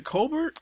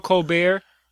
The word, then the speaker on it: Colbert: Because like if you look at it, it would be pronounced Colbert or something like that. If you looked at it phonetically, like the nigga shit I just pulled. – Yeah, Colbert. So you would look at it phonetically, it would be like the Colbert Report.